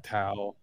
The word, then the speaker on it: Tao: You just pronounce the T with a D. – Oh, okay. Well, you can say Tao or Dao is the same thing.